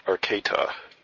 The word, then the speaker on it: Arcata: There, 1913 Hotel, radar contact 5 miles southeast of Fortuna, VOR, Arcata altimeter 3025, and advise approach request. – Yeah, we're going to request the RNAV GPS 32, and the airport's pronounced Arcata, 1913 Hotel.